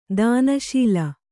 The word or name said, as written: ದಾನಶೀಲೆ